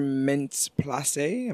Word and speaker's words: Mintz-Plasse: Well, it's funny because Christopher Mintz-Plasse, am I saying that right? – Yeah.